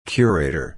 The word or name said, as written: curator